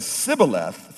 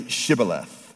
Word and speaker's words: Shibboleth: These men could not say Shibboleth, but they would say Sibboleth, which basically means cursing, not life.